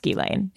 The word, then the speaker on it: Ghislaine: Who I found out her name is pronounced Ghislaine.